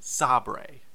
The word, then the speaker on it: Sabre: But at this time, the office believes that the company will be pronounced Sabre. – Which is a very interesting choice of pronunciation to say it's Sabre. It is spelled S-A-B-R-E.